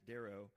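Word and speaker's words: dero (δέρω): But the word in the Greek is dero, and there it is in the Greek, and then that's how you pronounce it, dero.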